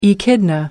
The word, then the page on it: echidna: British English